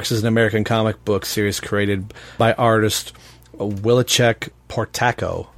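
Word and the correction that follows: Whilce Portacio: if I'm saying that right. – Oh, Wallace Portacio.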